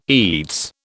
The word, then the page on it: eats: British English